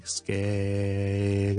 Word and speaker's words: scale: scale scale，咁所以听落去咧个感觉上就系好似一个好长嘅 vowel，而呢个 vowel 系不停转变紧嘅，先系 scale，跟住 e scale，跟住再 u 翻 scale scale scale，所以 technically it's still one single syllable， okay，唔系两个 syllable or three syllables， but it's a changing vowel。